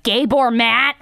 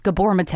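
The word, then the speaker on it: Gabor Maté: I've been pronouncing that motherfucker's name wrong when I mentioned him months ago, and I'm very sorry about that. I was like, Gabor Matt. It's Gabor Mate, okay?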